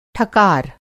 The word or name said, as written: ठकार